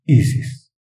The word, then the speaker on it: Isis: al tiempo que pronunciamos el mantra Isis. Vocalizando la S con un sonido sibilante como el del aire.